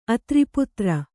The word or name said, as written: ಆಧಾರಪತ್ರ